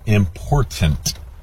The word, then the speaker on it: important: The problem is you're mispronouncing it. It is important, Like my girlfriend and wife say, mind the T's.